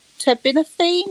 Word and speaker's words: terbinafine: So I went back, and still thinking it was fungal, they put me on, you'll have to correct me if I pronounce it wrong, but terbinafine or terbinafine? – Terbinafine.